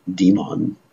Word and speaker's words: demon: So try to understand when Prabhupada uses the word demon, or as Prabhupada would pronounce it, demon.